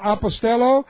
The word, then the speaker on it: apostello: The Greek word pempo, P-E-M-P-O, is one of the words. And the other word is apostello. All right, apostello, A-P-O-S-T-E-L-L, long O.